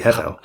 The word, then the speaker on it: Hevel: And so this is where the transliteration of the Hebrew words into English is written with a B, but actually it should be pronounced as a V. So actually Abel should be something like Avel.